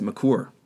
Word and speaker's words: Maker: He apparently his last name is pronounced McCour or McCur.